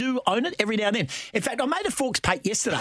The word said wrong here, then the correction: faux pas: Oh, can you say it properly, please? Please. – A faux pas. – Thank you. – F-A-U-X.